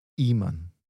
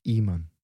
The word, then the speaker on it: ímã: So has that got the same ending as the word for sister? – That's right. So irmã, and then here we have ímã. But the stress is on I. Ímã.